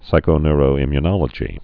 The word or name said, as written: psychoneuroimmunology